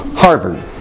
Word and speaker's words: Harvard: Harvard. Silent R. Harvard.